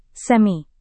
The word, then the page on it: semi: indonesian